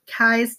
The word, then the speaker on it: Keyes: Keyes, like Cobra Kai.